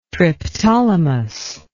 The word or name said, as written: Triptolemus